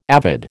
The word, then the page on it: avid: American English